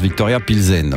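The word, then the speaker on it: Viktoria Plzeň: Pour leur premier match de la phase de ligue, les Saint-Gillois ont facilement dominé 0-3 les Tchèques du Victoria Plains. – Classique 21, Coffee on the Rocks. Raphaël Scaigny. – Je crois même que ça se prononce Victoria Pilsen. Voilà, je pense.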